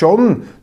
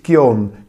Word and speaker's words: Gion: Und sein Bruder, Jerrys Bruder ist John, nicht Gion, John Matthias Gavelti, ein Schriftsteller, Surkamp Verlag, ein äh, brillanter Autor mit einem Flair für Stephen King und auch für Hardrock-Musik.